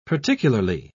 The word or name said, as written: particularly